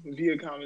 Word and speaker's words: Viacom: Okay, so Viacom, we're going to go with Viacom. Viacom is how you pronounce it.